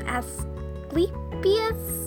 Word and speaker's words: Asclepius: I think it's pronounced Asclepius, but maybe... Actually, yeah, that sounds right.